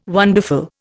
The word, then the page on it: wonderful: Indian